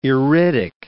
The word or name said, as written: iridic